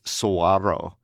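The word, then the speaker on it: saguaro: I mean, I always say it's pronounced suaro. And if you're pronouncing it because it's in your front yard, then you are not a Las Vegan because they are illegal here. So don't do that. So that's it. If you pronounce it the right way, then you are not from here.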